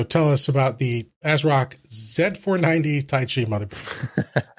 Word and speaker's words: Z490: He's got a review for us of a Z490. Yes, the Z400 series are out, and here's it's pronounced Zed. Z490.